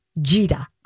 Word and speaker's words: Gita: It's pronounced Gita. I read Twin Cities Business. – I edited that story. – Well, then you didn't read it because her name is G-I-T-A, pronounced J-E-E-T-A-H.